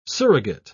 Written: surrogate

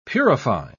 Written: purify

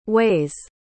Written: way's

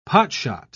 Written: potshot

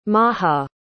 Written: maha

